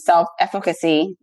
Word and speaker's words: self-efficacy: And you talk about this, the self-efficacy.